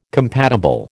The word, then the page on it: compatible: American English